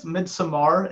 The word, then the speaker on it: Midsommar: I think it's Midsommar.